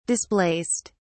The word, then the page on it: displaced: British English